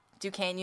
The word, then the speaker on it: Duquesne: But yeah, Duquesne University. That is, I am 100% sure that's how that's pronounced. It is spelled Duquesne.